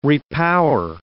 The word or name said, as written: repower